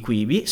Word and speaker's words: Quibi: Si dice Quibi, con una certa flemma, Quibi. – Quibi. – Il nome riprende Quick Bites, ovvero piccoli morsi, piccoli video. Perché? Perché tutti i contenuti di Quibi sono pubblicati su questa piattaforma in appunto byte da 7-10 minuti.